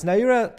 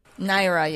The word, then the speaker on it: Naira: Is Naira the right way to pronounce the currency? – Naira, yes. It's pronounced Naira.